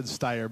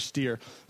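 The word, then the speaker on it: Stier: So I said Steyer, but it's Greg Steer.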